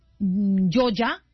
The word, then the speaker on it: Yolla: También la aplicación Yoya, se escribe Yolla, y Reptel.